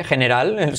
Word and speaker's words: general: Letra clave G, general, se escribe igual, pero se dice general.